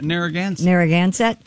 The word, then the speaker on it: Narragansett: Narragansett.